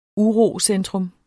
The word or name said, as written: urocentrum